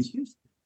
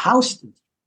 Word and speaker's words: Houston: which is spelled Houston, like the city, Houston. So it looks like it should be Houston, except that's not what it's called. It's called Houston.